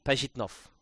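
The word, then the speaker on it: Pajitnov: Transliterated as Alexei Pazhitnov. So it's Pazhitnov.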